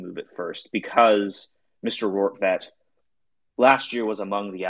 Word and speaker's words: Rortvedt: On Sunday, when the Yankees traded for Josh Donaldson and Isaiah Kiner-Falefa and Ben Rortvedt, getting that right. If you haven't heard his name, that's how you say it, according to Baseball Reference.